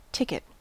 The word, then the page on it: ticket: American English